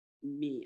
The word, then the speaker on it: mi: In music, the 528 hertz sound frequency refers to the note mi. I'm going to say that the I in here is pronounced like an E. So it's M-I in quotations.